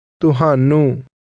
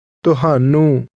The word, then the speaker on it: Tuhanu: Punjabi is a tonal language, which means that when you pronounce certain words, the pitch of your voice contributes to the meaning. If one of these words is pronounced with a different pitch or tone, it can have an entirely different meaning. – Tuhanu is one of these words, and it is pronounced with a tone that falls to a low level.